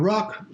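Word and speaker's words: RUC: The ruck still rhymes with yuck,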